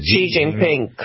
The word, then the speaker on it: Xi Jinping: Number eight is Xi Jinping. Did I pronounce that right? I never know that I pronounce it right. – Xi Jinping, correct.